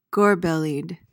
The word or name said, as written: gorbellied